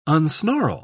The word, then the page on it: unsnarl: British English